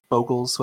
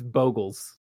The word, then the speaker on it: Bogles: You say Boogles? – Yeah, Boohooglin. However you pronounce the deck. But that's the closest deck. Boogles? I think that's how you pronounce it. Yeah, Boogles. Yeah. – It's Bogles. – Okay. – Yeah. No. It is. It's been confirmed.